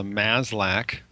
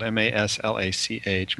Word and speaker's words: Maslach: Burnout is actually a cluster of symptoms, and it's measured usually by something called the MASLAC, that's spelled MASLACH, MASLAC Burnout Inventory, named after a researcher named Christina MASLAC, who started measuring burnout in people in the 1970s.